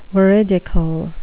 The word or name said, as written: veridical